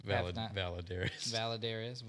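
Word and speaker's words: Valladares: By Oscar Valderez. – It's Valadares. – Valadares. What? – You said Valderez.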